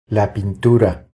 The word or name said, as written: La pintura